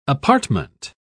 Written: apartment

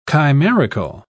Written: chimerical

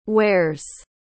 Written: wares

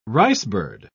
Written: ricebird

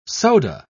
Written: soda

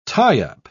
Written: tie-up

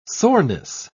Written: soreness

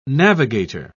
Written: navigator

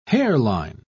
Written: hairline

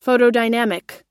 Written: photodynamic

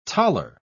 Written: taler

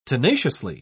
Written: tenaciously

